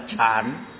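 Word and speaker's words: chán (禪): Tiếng phổ thông gọi là chán. Chán. Đọc là chán.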